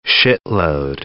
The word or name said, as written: shitload